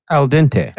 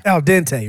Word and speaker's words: al dente: Andante? What is it? How do you say it? – Al dente. – Al dente, right.